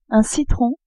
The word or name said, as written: citron